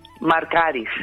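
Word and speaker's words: Markaris: Marcaris.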